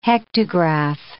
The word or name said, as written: hectograph